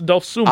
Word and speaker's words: Dosunmu: It's not even spelled like Dosunmu either. – It ends in a U. – It's O and U. It's Dosunmu.